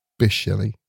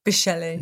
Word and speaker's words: Bysshe: I was trying, was it Bysshe? Bysshe? And luckily I listened to some podcasts and it came out as Bysshe. – Bysshe.